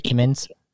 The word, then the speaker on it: Kimmons: Today, I have Ronald Kimmons. That's how you pronounce it, right, Kimmons? – Yeah, Kimmons, yep.